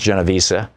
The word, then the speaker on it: Genovese: Her name was Kitty Genovese, and sometimes it's pronounced Genovese, who lived in New York.